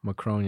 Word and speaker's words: Macron: Hold on, how do you say the president's name? Macron? – Macron, yeah.